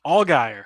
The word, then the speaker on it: Allgaier: So you started with one that actually did cash, um, and that was Justin uh, Algier over Gregson. I always say his name wrong. Allgaier. Allgaier. Come on, man.